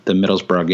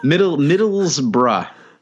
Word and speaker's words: Middlesbrough: I mean, Baines actually looked pretty good from an attacking perspective in the Middlesbrough game as well. – Excellent pronunciation of Middlesbrough. – I tried. Yeah. Middlesbrough, I think, right? – It's good.